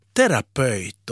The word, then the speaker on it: terapeut: Sverigesvenskar, de kan rygga lite baklänges när de hör hur finlandssvenskar uttalar orden terapeut och farmaceut. För det heter nämligen terapeut och farmaceut. Var kommer detta ö ifrån?